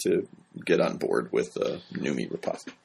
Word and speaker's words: Noomi Rapace: Noomi Rapace. – Oh, is that how she? – Yeah. – Why did I think it was Rapace? – I'm not sure.